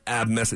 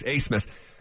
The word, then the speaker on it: Abmas: They have a guard named Max Asmus, but it's spelled A-B-M-A-S. It's not Abmus. It's Max Asmus.